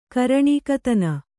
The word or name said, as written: ಕರಣಿಕತನ